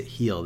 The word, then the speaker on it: Gil: And yeah, I guess Gil pronounces it Heel. That's really interesting. Eovaldi versus Heel, though, is going to be a kind of an interesting matchup.